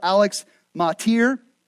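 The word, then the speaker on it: Motyer: I've already referenced the Old Testament scholar Alex Matir. That's the correct way to pronounce his name. I don't think last week I pronounced it right.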